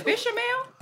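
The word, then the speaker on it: béchamel: You said bechamel? – Bechamel. – No T sound at the end, bechamel.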